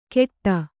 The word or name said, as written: ಕೆಟ್ಟ